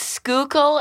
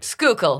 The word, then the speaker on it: Schuylkill: Well, technically, it's Schuylkill, and people will be very angry if I say it wrong.